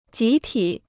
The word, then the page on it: 集体: jí tǐ